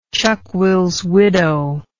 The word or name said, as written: chuckwillswidow